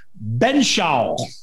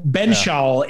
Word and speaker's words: Benzschaul: So I'm going to do the best we can and say it's Bo Benshaw. Bo Benshaw, yeah, is how I believe, and I remember his name being pronounced. Benshaw, spelled Benzschaul.